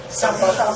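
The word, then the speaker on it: sampa: samba。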